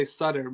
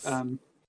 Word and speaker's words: Sutter: Yeah, I mean I say Sutter, but Sutter. I guess it's an Americanized version of Sutter, so or something.